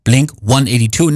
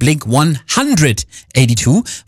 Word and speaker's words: Blink-182: Und die heißen ja eben Blink-182, nicht Blink-182, weil sie sich für die britische Aussprache entschieden haben, weil das so schön klingt, sagen die Jungs aus San Diego, USA.